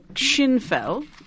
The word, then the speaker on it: skinnfell: It's a Schinfell, and a Schinfell looks, in English, it looks like a skinfell. So it's spelled S-K-I-N-N-F-E-L-L.